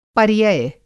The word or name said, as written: पर्यय